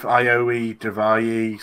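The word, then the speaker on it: Ariya Daivari: I've never heard of Ayari Daivari, so I'm going for Tony Nese. – That was the best pronunciation of his name, I think. You've got that spot on.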